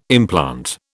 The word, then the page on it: implant: British English